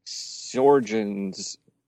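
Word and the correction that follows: Sjögren's: Oh, I can't even say it right. It's one of those Swedish things. Sjogren's syndrome.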